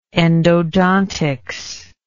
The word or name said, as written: endodontologist